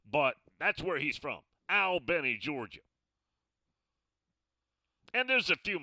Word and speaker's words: Albany: Yeah, it's spelled just like Albany, but like we told you, it's pronounced Albany.